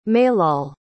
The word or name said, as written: maillol